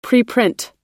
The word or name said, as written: preprint